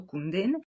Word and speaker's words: Kundun: A je to film Kundin, psáno Kundun, ale čte se to Kundin.